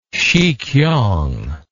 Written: sikiang

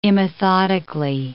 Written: immethodically

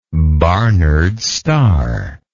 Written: Barnard's Star

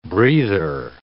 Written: breather

